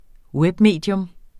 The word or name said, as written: webmedie